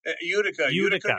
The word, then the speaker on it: Utica: Utica. – Utica, New York, which is central New York.